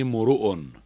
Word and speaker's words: امرؤ: امرؤ، إن امرؤ هلك في الكتاب الكريم، إن امرؤ هلك، تضم الميم والراء في رفع الكلمة، إذا كانت الكلمة مرفوعة فضم ميمها وراءها، قل امرؤ، إن امرؤ هلك، جاءني امرؤ.